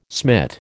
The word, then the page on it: smit: American English